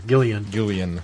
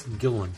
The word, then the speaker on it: Gillan: I always want to put an extra syllable in there. Gillian. It's Gillan.